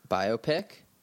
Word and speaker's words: biopic: You, everyone, your mom, your dad, your brother, your sister, anyone and everyone should go see Bohemian Rhapsody, the new biopic on Freddie Mercury and Queen. – Did you just say biopic? – Biopic? How is it pronounced? – Biopic, like biography picture. Biopic.